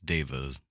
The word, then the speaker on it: deva: How do you say it, deva? – They might be diva devas, but I think it's devas. – D-E-V-A.